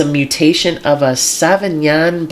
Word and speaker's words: Savagnin: Because it's spelled very similar to Sauvignon Blanc. But I would pronounce it Savagnin.